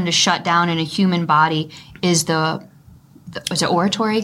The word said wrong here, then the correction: auditory: Is that how you say it? – Auditory.